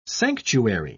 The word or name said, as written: sanctuary